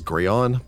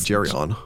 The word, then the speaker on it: Geryon: Which, again, I keep wanting to pronounce as Grayon, but apparently it is Geryon.